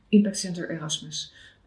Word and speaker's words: Impact Center Erasmus: Zeg ik het goed? – Ja, ja. Impact Center Erasmus, ja. – Impact Center Erasmus.